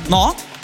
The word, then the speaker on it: nu: Das wird geschrieben N-U. Nu wird aber no ausgesprochen. Im Sächsischen. Zum Beispiel habe ich einen Kellner in einem Restaurant gefragt, ah, da hinten ist ein Tisch frei.